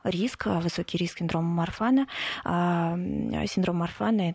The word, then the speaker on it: Марфана: А, у меня синдром э, Марфана, правильно я ударение ставлю?